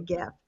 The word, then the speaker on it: GIF: And I get excited to pick out the perfect, I call it a GIF. Some people say a GIF. – Yeah. – But I do the soft G.